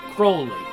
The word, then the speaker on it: Crowley: But his real name, it's not his real name, but his name that he preferred was Crowley. Because, again, it would rhyme with holy.